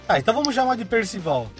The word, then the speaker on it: Percival: É Percival? Percival? – É Percival. – Percival, né? – É, escreve-se Percival, assim como se escreve Arte 3Miss. A pronúncia certa é Percival.